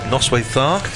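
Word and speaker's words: Noswaith dda: To welcome Noswaith Thar to Kenneth Davis. I'm sorry about the pronunciation. – Brilliantly pronounced.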